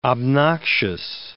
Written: obnoxious

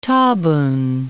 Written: tabun

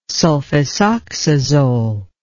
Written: sulfisoxazole

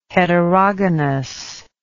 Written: heterogonous

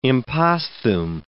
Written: imposthume

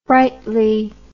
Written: brightly

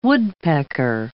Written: woodpecker

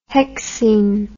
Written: hexene

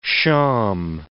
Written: shawm

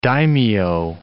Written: daimio

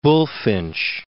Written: Bulfinch

